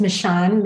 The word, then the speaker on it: Michon: My name is Michon Mayfield. And my first name is spelled M-I-C-H-O-N.